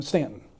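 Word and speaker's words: Staunton: I'm Stanton. – Oh, okay. And you say that right also.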